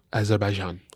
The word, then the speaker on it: Azerbaijan: Is that how you say it? – Azerbaijan. – Azerbaijan. – Not Baku. – Not Baku. Baku's the city.